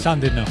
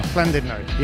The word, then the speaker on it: Llandudno: Hello and good evening from Llandudno. Llandudno. Did I say that right? Llandudno. – Llandudno. – Llandudno. Yeah, there's nothing worse than an Englishman trying to say Llandudno, is there?